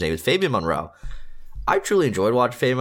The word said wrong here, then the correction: Moreau: It's Moreau, not Monroe.